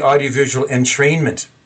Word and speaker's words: audiovisual entrainment: Can you talk about your audiovisual entertainment, your cranial electrostimulation, and your transcranial DC stimulation? – Yes, actually, I've been talking about the audiovisual entrainment, is how you pronounce that.